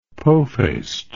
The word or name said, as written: pofaced